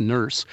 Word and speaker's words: Nourse: Nurse, N-O-U-R-S-E, but pronounced Nurse, about a human colony on Titan.